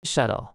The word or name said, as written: shuttle